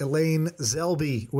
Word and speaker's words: Elaine Zelby: And then we have Elaine Zelby with us.